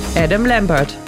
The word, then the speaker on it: Adam Lambert: Adam Lambert.